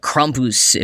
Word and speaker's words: Krampus: Or Krampus, if I were to pronounce it slightly more correctly.